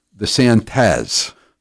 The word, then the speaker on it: DeSantis: DeSantis. That's with a Z. – He's Hispanic now. He's Latinx. – DeSantis.